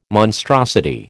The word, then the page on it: monstrosity: American English